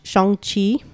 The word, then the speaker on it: Shang-Chi: Unlike you, I did watch Shang-Chi.